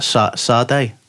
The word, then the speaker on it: Sade: How do you say that again? Sade? – Sade. – Sade. – It's like Sarah.